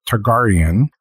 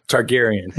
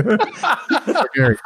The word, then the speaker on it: Targaryen: This is actually the Cardew Gold Reserve, House of Targaryen. And Targaryen. – He's not a Game of Thrones watcher. – Targaryen, sorry.